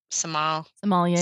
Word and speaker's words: sommelier: The sommelier.